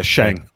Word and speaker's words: Sheng: Yeah, Sheng.